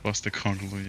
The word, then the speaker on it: Postecoglou: Ainge, the coach from Postecoglou. – Postecoglou, yeah.